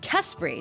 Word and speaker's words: Kespry: Eye in the Sky, or the creator of An Eye in the Sky, George Mathew, Chief Executive Officer of Kespry. Did I say it correctly this time? – Yes, you did.